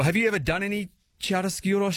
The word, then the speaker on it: chiaroscuro: I just remember chiaroscuro.